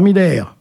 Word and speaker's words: Miller: Gérard Miller, chroniqueur, à prononcer à l'allemande, Jean-Luc Morandini, animateur, Patrick Poivre d'Arvor, journaliste, Tariq Ramadan, islamologue.